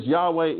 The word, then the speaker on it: Yahweh: He says Yahweh is proper way to say the Yod-Heh-Vah-Heh name. Yahweh is proper, the best way we can say it.